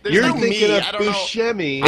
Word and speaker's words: Buscemi: Buscemi, isn't it? – Yeah. – God, how do you say his name? – Gary Buscemi. – Buscemi.